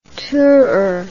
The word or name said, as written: tooter